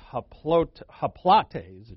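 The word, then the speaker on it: haplotes: Again, the Greek word is haplates, it should be pronounced, and it means to be singular.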